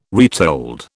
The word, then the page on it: retold: British English